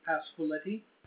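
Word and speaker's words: Pasqualetti: Pasquiletti.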